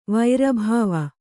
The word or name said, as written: ವೈರಭಾವ